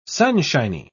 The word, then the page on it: sunshiny: British English